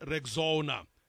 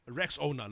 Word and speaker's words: Rexona: A friend even called it Rexona. But, guys, it's Rex Owner. Like Rex Owner, Rexona.